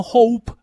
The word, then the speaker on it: Hopp: Le digo Hope, no Hope, porque tiene doble P. – Deletréamelo, se escribe, por favor. H-O-P-P de papa. O sea que es Matthew Hope, no Hope. Si bueno, fuera una sola P sería Hope, pero como es doble P es Hope.